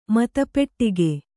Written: ಮತಪೆಟ್ಟಿಗೆ

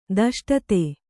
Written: ದಷ್ಟತೆ

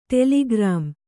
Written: ಟೆಲಿಗ್ರಾಮ್

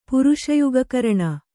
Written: ಪುರುಷಯುಗಕರಣ

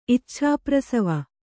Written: ಇಚ್ಛಾಪ್ರಸವ

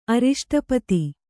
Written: ಅರಿಷ್ಟಪತಿ

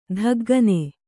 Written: ದಿಗ್ಗನೆ

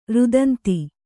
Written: ರುದಂತಿ

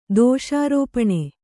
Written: ದೋಷಾರೋಪಣ

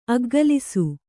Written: ಅಗ್ಗಲಿಸು

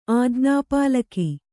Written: ಆಜ್ಞಾಪಾಲಕ

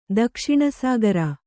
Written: ದಕ್ಷಿಣಸಾಗರ

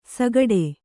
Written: ಸಾಗಾಡೆ

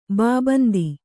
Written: ಬಾಬಂದಿ

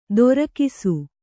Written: ದೊರಕಿಸು

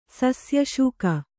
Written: ಸಸ್ಯಶೂಕ

